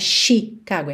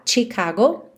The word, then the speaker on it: Chicago: A pronúncia dessa palavra tem um ch, ch, não é Chicago, é chi, chi, ok? Tipo tchau. TCH, Chicago. Western Mail.